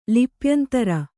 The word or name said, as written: ಲಿಪ್ಯಂತರ